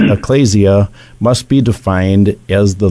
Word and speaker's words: ecclesia: Did I pronounce that right, Matt? – Ecclesia. – Ecclesia. Thank you.